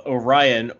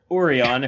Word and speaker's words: Orion: Well, shall we talk about Episode 17, The Pirates of Orion, or Orion, if you want to say it wrong, like they do here.